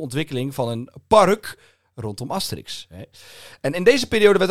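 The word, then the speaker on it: Astérix: Daar opende Park Asterix. Of zoals de Fransen zeggen, Park Asterix. Die hebben natuurlijk de klemtoon. – Asterix. – Asterix, die hebben de klemtoon achterin.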